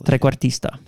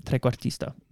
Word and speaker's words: trequartista: Trequartista. – That. So that means three-quarters midfielder. So you can just say trequartista. That works too, but trequartista. Yeah. Roll your R's.